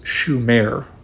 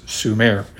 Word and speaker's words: Sumer: Growing up and reading about Sumer, I read somewhere that the correct, correct pronunciation was Shumer, with an SH at the beginning. However, in my recent preparation for this episode, I cannot find any such pronunciation. Instead, people seem to pronounce it as it reads, Sumer, with a simple S you may have heard me alternate these pronunciations in earlier podcasts when I made future reference to this ancient realm.